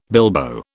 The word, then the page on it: bilbo: British English